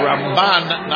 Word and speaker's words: Ramban: My mind is this Rambam with the accent on the first syllable. And the Ramban, Nachmanides, we put the accent on the second syllable, Rambam.